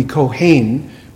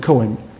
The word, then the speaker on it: Cohen: And I think literally in Hebrew it would be Kohen, but we would pronounce it Cohen.